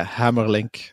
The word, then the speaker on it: Hamerlinck: Is it Hamerlinck? – Yeah, Hamerlinck. It's perfect like that. Americans do tend to say Hamerlinck. It sounds cooler, but it's Hamerlinck.